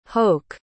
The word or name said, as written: hoch